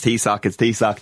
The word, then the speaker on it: Taoiseach: How do you pronounce that? It's Taoiseach.